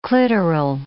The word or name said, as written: clitoral